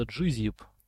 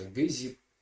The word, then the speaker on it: GZIP: языке это буква G, поэтому, да, по-русски, если говорить, говорят обычно GZIP, но вообще написана все-таки английская буква, и она в английском алфавите она произносится G, и обычно вот в американском сегменте YouTube, если ты послушаешь, там обычно говорят GZIP, и я немножечко из-за этого, поэтому привык так произносить.